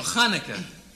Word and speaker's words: Hanukkah: Chanukah, that's beautiful. – What do you mean, People. Yeah, Hanukkah. – No, Hanukkah. CH is pronounced Huch.